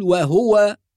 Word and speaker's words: وهو: أيضا نقول وهو ولا نقول وهو، فبعض الناس يسكن الهاء فيقول وهو، وبعض الناس يجعل الضم خفيفة يعني غير كاملة، وهذا يسمى في التجويد اختلاس، والاختلاس بمعنى الإسراع. فمن ينطق مثلا وهو وهو، هو يقرأ بضمة ولكن ضمة غير كاملة، هذا خطأ، والصواب تحقيق الضمة وإتمام الضمة، ضمة كاملة، ها ضمة هو وهو.